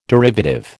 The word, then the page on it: derivative: American English